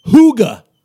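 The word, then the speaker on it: hygge: And you've been teaching me a little bit about this thing called HOOGA.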